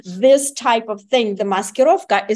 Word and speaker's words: maskirovka: You just nailed it, Dr. Savage, and you even pronounced it perfectly. Maskirovka.